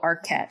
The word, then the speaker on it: Arquette: How do I say his last name? Oh, Arquette.